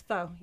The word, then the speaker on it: pho: It's pho, yeah, or probably pho, but American pronunciation, pho.